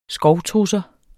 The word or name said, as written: SKOVTRUSSER